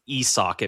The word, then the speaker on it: Isak: mostly because I don't like that it's pronounced Isak. It makes the hairs on my arms stand up by not saying Isaac and saying Isak.